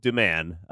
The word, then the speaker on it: Domann: It's not. How did you say it? That's not how you say it. – Doman? – How's it spelled? – D-O-M-A-N-N. Doman? Johansson JoJo Doman. – Doman.